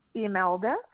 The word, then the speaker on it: Imelda: Imelda Sastrawinata.